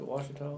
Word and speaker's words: Ouachita: We were talking about this yesterday, about the Ouachita River. – Yes. – And is that how you pronounce it, Ouachita? – Ouachita. – Yeah.